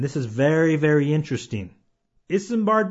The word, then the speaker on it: Isambard: His first name is Isambard, and you spell that I-S-A-M-B-A-R-D, Isambard. And his last name is Brunel, and that is spelled B-R-U-N-E-L, Brunel.